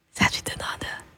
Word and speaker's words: Satchidananda: Satchidananda.